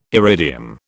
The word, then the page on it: iridium: British English